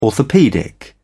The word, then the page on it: orthopaedic: British English